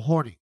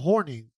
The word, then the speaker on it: Hornung: 1963, Paul Hornung. 1983, Art Schittler. 2019, Josh Shaw, which I didn't realize. And 2022, Calvin Ridley. Now, apparently, Alex Karras and Paul Hornung. The guy from Notre Dame. – Hornung.